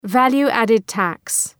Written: value added tax

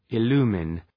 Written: illumine

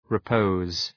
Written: repose

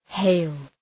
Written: hail

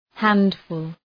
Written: handful

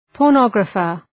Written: pornographer

